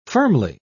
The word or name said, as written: firmly